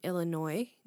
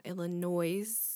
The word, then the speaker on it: Illinois: Yes, it's pronounced Illinois, not Illinois, or whatever people say.